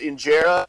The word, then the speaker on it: injera: I believe it's pronounced injera.